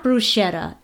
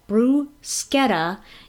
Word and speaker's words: bruschetta: It's not bruschetta. It's bruschetta.